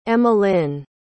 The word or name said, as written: emmalyn